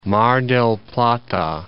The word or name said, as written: Mar del Plata